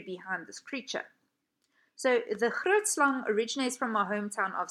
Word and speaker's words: Grootslang: And now, as you can hear how I'm pronouncing it, Grootslang. A lot of international people pronounce this creature's name as Grootslang, which I absolutely, it tickles me pink, I will say.